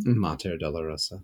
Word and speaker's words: Mater Dolorosa: Mater Dolorosa.